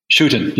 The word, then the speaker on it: Schouten: Schouten, yes.